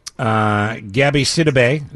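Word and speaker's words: Gabourey Sidibe: Gabby Sidibe, is that how you pronounce That's exactly how you say her name.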